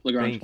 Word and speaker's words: Lagrange: Lagrange.